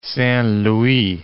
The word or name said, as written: Saint-Louis